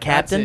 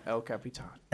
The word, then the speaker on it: Capitan: That's Capitan. How we're pronouncing it? No, not Captain? That's it. – No, El Capitan.